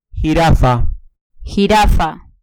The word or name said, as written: jirafa